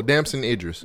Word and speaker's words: Damson Idris: Desmond? – Oh, Damson Idris.